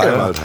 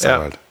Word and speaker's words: Seiwald: Seiwald heißt er. Er ist Seiwald. – Ja. Ähm, Sei Wald, ist doch logisch. Sei Wald.